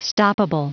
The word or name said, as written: stoppable